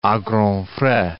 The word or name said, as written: a grands frais